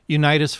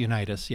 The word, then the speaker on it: Unitas: Unitas Photography. – Unitas, yeah.